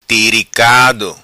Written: Tiricado

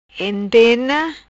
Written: Entena

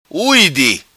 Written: Ui Di

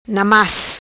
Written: Na Mas

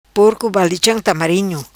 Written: Porco balicham tamarinho

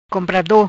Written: Comprado

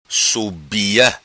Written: Subia

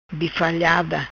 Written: Bifalhada

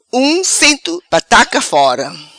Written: Um cento pataka fora

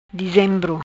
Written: Dizembro

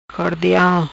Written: Cordial

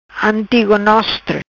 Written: Antigonostre

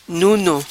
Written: Nuno

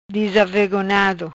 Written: Disavegonhado

